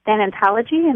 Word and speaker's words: thanatology: And you are certified in thanatology. Am I pronouncing that correctly? – Thanatology. Yes, exactly.